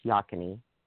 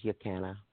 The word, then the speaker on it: Yocana: It's called, spelled Yocana, but pronounced Yakini. I think the original pronunciation was Yocana, but got changed over the years.